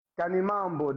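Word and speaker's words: Khanimambo: Canimambo, that's how we say thank you in Portuguese. No, not in Portuguese, in our national language here. – Say it again for me so I can say it right. – Canimambo. Is how Canimambo. – We say thank you in Sangana.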